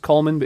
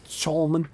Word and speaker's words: Chalmers: It looks like it's pronounced Coleman, but it's Chalmers.